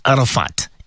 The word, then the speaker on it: Arafat: whenever I say my name Arafat, so I always have this in mind, that I remember few years ago, the listeners used to say Arafat, double 80. Yeah, so it's Arafat, A-R-A-F-A-T, Hatia.